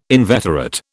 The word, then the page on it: inveterate: British English